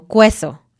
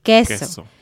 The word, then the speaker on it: queso: Entonces, dicen cosas como cueso en lugar de queso. Uh-huh. La Q es una excepción. Siempre va a haber una U después, pero siempre va a sonar como K y nunca se va a pronunciar la U. Entonces, quiero, queso, quito. Estas palabras, la U no suena.